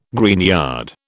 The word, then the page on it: greenyard: British English